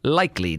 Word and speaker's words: Likekele: He does not spell it L-I-K-E-L-Y. He spells it L-I-K-E-K-E-L-E. Likely is kind of how it looks, but it's pronounced likely.